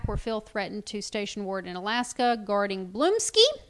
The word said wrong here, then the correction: Blonsky: Blonsky. Yeah, the Abomination. – Oh, I mispronounced it.